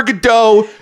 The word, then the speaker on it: Godot: I've done Waiting for Godot. And my theater instructor told me that according to the playwright, it's actually pronounced Godot.